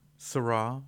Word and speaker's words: Syrah: That is not how you say that. – Okay, how do you say it? How do you say it? – That is Blanc de Bois, Syrah, Merlot, and Caminera, New York.